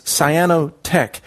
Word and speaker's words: Cyanotech: That's Cyanotech. It's spelled like the color cyan.